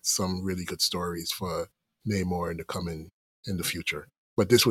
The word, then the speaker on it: Namor: And I'm not pronouncing it the MCU way because he's not the MCU character. And so Namor, just...